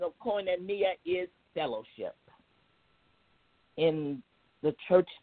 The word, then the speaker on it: koinonia: The lesson I want to focus on today is koinonia. I want you to try saying that. Koinonia. And it's spelled K-O-I, for those who are taking notes.